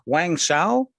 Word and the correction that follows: Wang Zhao: Wang Zhao, close enough.